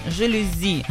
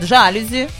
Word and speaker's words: жалюзи: В слове «жалюзи» ударение ставится на третий слог, да, с буквой «и». Именно «жалюзи». Угу. Очень часто люди ставят ударение жалюзи на первый слог, да, на букву «а» и говорят «жалюзи». Угу. «Жалюзи». А это неправильно. Ударение на последний слог обусловлено французским происхождением слова.